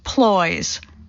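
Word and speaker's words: ployes: Maine, ploys. Not quite crips. Ploys, rhymes with boys, are similarly thin, griddled goodies that cook on just one side.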